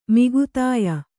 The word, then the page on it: ಮೌಗ್ಧ್ಯ: kannada; maugdhya